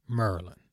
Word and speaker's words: Maryland: Or as we say here in the DMV, Maryland. Yeah, you heard that right. Maryland. Yeah, it's spelled M-A-R-Y-L-A-N-D, but we call it Maryland.